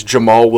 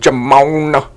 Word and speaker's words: Jamaal: and I can't stop saying this guy's name wrong, but it's Jamaal Williams, but I'm calling him Jamon Williams uh, of the Green Bay Packers.